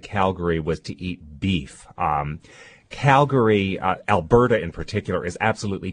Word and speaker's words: Calgary: We went to Calgary, Canada, which I will tell you right now is pronounced Calgary, not Calgary. That's a terrible faux pas.